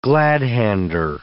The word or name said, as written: glad-hander